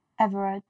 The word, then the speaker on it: Everard: Yeah, you know the whole Sarah, how do you pronounce her last name? – Everard.